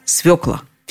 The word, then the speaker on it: свёкла: Все современные словари, в которых проставлено ударение, приводят единственно правильный вариант – свекла.